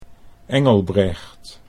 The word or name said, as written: Engelbrecht